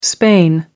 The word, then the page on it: Spain: American English